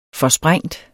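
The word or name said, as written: FORSPRÆNGTE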